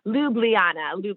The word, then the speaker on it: Ljubljana: I need to figure out how to say it. Ljubljana. – There we go.